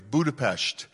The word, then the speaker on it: Budapest: I did pronounce that correctly, Budapest. And that's how the Hungarians pronounce it, Budapest.